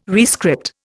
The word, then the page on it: rescript: Indian